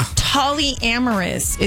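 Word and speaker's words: Tolyamorous: Tollyamorous is how you say it. T-O-L-Y. Tollyamorous.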